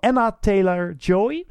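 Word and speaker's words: Anya Taylor-Joy: Thomasin, gespeeld door Anna Taylor-Joy.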